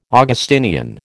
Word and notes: Augustinian: American English

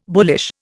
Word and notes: bullish: Indian

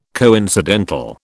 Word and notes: coincidental: British English

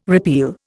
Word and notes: repeal: Indian

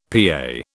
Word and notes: PA: British English

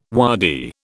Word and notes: waddy: British English

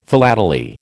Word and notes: philately: American English

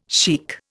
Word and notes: sheikh: Indian

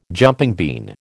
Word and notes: jumping bean: American English

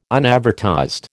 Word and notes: unadvertised: American English

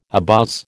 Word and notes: abuzz: American English

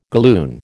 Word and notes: galloon: American English

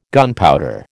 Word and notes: gunpowder: American English

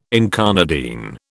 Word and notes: incarnadine: British English

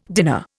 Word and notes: dinner: Indian

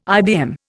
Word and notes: IBM: Indian

